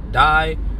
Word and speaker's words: DAI: I pronounce it DAI. Some people say day.